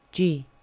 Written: g

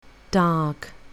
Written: dark

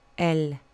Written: l

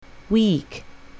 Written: weak

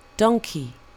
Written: donkey

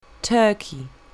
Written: turkey

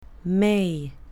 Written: may

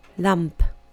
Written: lamp

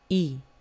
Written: e

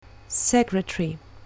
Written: secretary

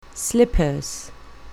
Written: slippers

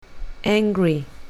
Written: angry

